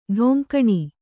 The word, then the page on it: ಢೊಂಕಣಿ: ḍhoŋkaṇi